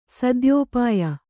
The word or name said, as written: ಸದ್ಯೋಪಾಯ